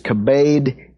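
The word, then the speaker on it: kabed (כבד): Now, in the verb form, it's pronounced kabed. In the noun form, it's pronounced kabod, but it's the same word.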